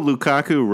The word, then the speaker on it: Lukaku: Even though Lukaku rhymes with seasonal flu, Big Rom did not have the flu this weekend, and he banged in 4 goals.